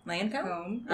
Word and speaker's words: Lancôme: Lancôme.